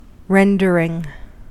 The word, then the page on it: rendering: American English